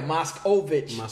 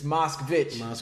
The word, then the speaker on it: Moskvich: Because people keep putting that O in there. – The extra syllable. – Yeah, Moskovich. And it's not Moskovich, it's Moskvich. I've learned this.